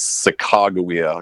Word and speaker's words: Sakakawea: Well, I think the actual pronunciation is Sakakawea, according to her specific tribe dialect or whatever it's referred to as, which has a C and a G, Sakakawea.